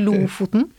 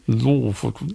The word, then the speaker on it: Lofoten: Nearly Lofoten. – Lofoten.